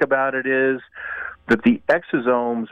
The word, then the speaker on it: exosomes: Is that how it's pronounced? – Yeah, no, exosomes, yeah.